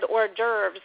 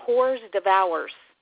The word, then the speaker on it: hors d'oeuvres: The first time I said hors d'oeuvres, I called them whores devours.